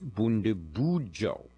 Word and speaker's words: Bundibugyo: It's actually pronounced Bundibugyo.